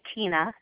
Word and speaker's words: Tina: It's Tina, T-I-N-A. And the last name is Day, like night and day, D-A-Y.